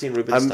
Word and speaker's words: Rubinstein: Is it Steen or Stein? – Rubinstein.